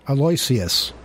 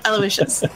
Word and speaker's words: Aloysius: Aloysius. – Aloysius? – All right, is that how you pronounce it? – That's how you pronounce it, yeah.